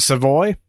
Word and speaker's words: Savoy: Is it Savoy? Am I saying that right? – Yes, sir.